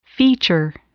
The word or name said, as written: feature